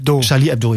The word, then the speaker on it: Charlie Hebdo: Also ein Anschlag auf die Satire-Zeitschrift Charlie Hebdo, spricht man das so aus? – Charlie Hebdo, ja, ich als super Frankreich-Ignorant so.